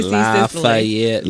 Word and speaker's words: Lafayette: That's how you say it in Georgia. Lafayette. You gotta have like seven syllables.